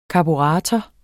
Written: karburator

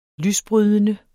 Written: lysbrydende

